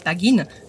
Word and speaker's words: bagging: ne prononcent pas le G à la fin, donc c'était fait baggin, pluriel baggins.